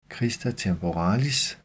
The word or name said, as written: crista temporalis